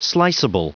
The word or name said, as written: sliceable